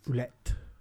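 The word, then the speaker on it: Ouellet: Is that how it's pronounced? – Oulet. – Yeah, with a T at the end.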